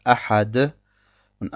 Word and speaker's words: أَحَدٌ: Am Ende haben wir das Wort Ahad. Und Ahad hat eine Runnah und zwei Dhammas über sich und wird deshalb Ahadun ausgesprochen. Und weil nach diesem Wort eine Hamza kommt in dem nächsten Vers, haben wir hier ein Izhar beim Weiterlesen.